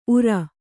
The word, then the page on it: ಉರ: Kannada